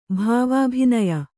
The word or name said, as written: ಭಾವಾಭಿನಯ